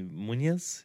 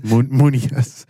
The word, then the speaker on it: Muniz: So Malcolm, which is Frankie Muniz, is that how you say it? – Muniz. Yeah, that sounds very Spanish. Muniz. – Is this Muniz? Muniz. How do you say his last name?